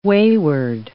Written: wayward

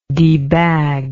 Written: debag

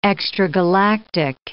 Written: extragalactic

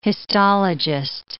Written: histologist

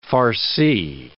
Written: farcie

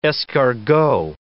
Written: escargot

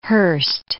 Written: Hurst

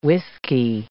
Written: whiskey

